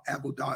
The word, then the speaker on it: Avodah: The term is Abodah. Abodah, as he pronounced it, A V O D A H, is the English spelling or one English spelling.